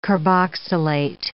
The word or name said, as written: carboxylate